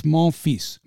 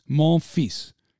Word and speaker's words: Monfils: It's Monfils, Monfils, Gael Monfils, okay? Please learn how to pronounce the name. Uh, and this isn't some hump that's never played before. He's been around for years. It's Gail Monfils, M O N F I L S, Fils.